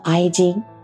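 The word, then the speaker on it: 愛人: 在日语里面呢，这个爱人的发音有的叫哀金，但是呢，在日语里面，这个哀金的这个意思呢就比较微妙，它有点负面的意思，就是第三者哀金。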